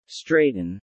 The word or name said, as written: straighten